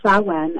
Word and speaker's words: Samhain: Halloween, or Samhain, as it's pronounced in Wicca,